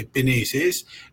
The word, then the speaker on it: Pinesis: I, I heard "penishish," which is how we say uh, bird in uh, in the N dialect. We say Pinesis.